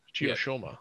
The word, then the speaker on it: Chiyoshoma: So yeah, Ichinojo, got Akua and Chiyoshoyama. Yeah, I said it right. Chiyoshoma. There we go. I was adding an extra syllable at the end there.